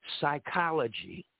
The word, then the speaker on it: psychology: We have psychology, P is silent.